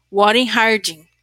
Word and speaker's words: Warren Harding: O erro de, e eu anotei aqui a pronúncia desse nome, porque tem gente que tem toque com pronúncia de nome, a gente quer aprender, mas tem hora, gente, que a música da fala, ela é muito ampla. É Warren Harding.